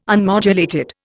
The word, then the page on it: unmodulated: Indian